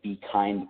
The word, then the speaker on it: Bkynd: The other thing that I am starting is my own company called the Be Kind Company. Be Kind is actually one word, and it's spelled B-K-Y-N-D.